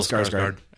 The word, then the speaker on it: Skarsgård: Is that how you say his name? Skarsgård? – Yes. – Yeah, Bill Skarsgård.